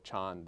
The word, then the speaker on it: Chan: This word in the title of my book, Chan, this is the Chinese pronunciation of the word Zen in Japanese, which is of course more well known in English.